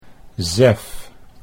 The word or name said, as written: Zef